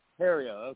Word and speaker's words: Theriault: Theriault.